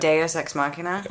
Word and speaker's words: Deus ex machina: doozy machina. How do you say it? – Deus ex machina.